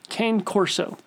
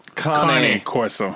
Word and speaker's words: Cane Corso: I'm going to say it wrong because I already know it's wrong because I listened to the episode. Cane Corso. – Connie Corso. – What? – Connie.